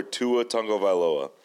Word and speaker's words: Tua Tagovailoa: It's QBs I'd take before Tua Tagovailoa. – Good pronunciation. – So, thank you. You've trained me well.